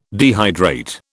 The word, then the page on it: dehydrate: British English